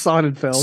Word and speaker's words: Sonnenfeld: I love that you have butchered literally every last name. It's Sonnenfeld.